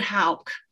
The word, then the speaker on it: Hawk: Well, I think that one of the things I noticed is how the characters And you've got Flatlanders, like Hawk from, is that how you pronounce it, from San Diego? – I pronounce it Hauk.